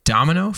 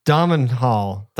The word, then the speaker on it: Domhnall: Did you just say Domino? First name Domino? – No, Domhnall. Domhnall. Domhnall.